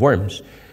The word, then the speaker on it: Worms: And then it was printed in Worms, as it's pronounced by the Germans.